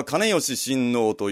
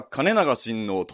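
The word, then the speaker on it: 懐良親王: ちなみに現在は金吉親王と読むことが一般的ですけれども、菊池の地では金長親王とどの看板にもルビが振ってありますので、金長親王という読みで本日は読んでいきます。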